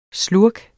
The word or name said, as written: SLURKENES